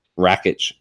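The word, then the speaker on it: Rakić: same for Rakic or Rakic. I always pronounce that wrong. – Rakic.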